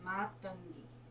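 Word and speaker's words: Matangi: Yeah, it's Matonki.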